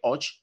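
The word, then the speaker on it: Hodeg: Álvaro José Och, que es como dicen que se pronuncia, el colombiano del de Keuning Quick Step.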